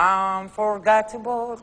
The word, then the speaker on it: Unforgettable: Sapete che Nat King Cole ha cantato con Natalie Cole questa canzone bellissima che è Unforgettable? No, perché non lo so dire. – No, è giusto.